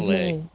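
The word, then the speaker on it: Méliès: It is Millais.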